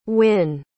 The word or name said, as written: wynne